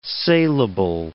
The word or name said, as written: saleable